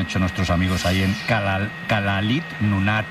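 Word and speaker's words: Kalaallit Nunaat: Nada, allí cuando juntan las dos L le ponen una S y una L. Kalalit Nunaat.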